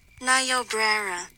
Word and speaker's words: Niobrara: Niobrara. What? – Niobrara. – Niobrara. You have to say it like that? – Niobrara. Like a child? – Niobrara. – I'm sure that's correct, too.